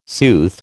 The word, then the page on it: sooth: American English